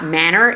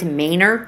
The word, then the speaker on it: Manor: It's not manner, it's manner. I'm like, we're not gonna do this, bro.